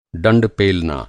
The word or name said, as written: डंड